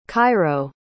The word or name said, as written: cairo